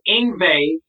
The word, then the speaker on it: Yngwie: So I put Yngwie in brackets, rhymes with Stingray. I thought that was pretty clever. – Yeah. – They rewrote that and put Yngwie rhymes with Oyve.